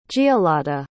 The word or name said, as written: giolladhe